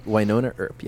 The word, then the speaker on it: Wynonna Earp: Yeah, Wynonna Earp, yes.